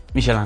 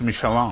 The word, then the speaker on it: Michelin: Así se pronuncia. – Michelin. – ¿Payaso? – No, sí. O sea, yo digo Michelin. – O sea, pero es las llantas. – Nadie le dice Michelin.